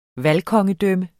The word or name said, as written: valgkongedømme